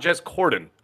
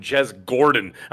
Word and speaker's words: Corden: Jez, not Jez Corden. Apparently, everybody else calls him Jez Gordon. I'm like, what is Flash Gordon? They can't get his name right.